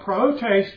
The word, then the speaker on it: prautes: The word for meekness is Prautes, and it's spelled P-R-A-U-T-E-S, P-R-A-U-T-E-S, pronounced Prautes.